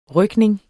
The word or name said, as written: rygning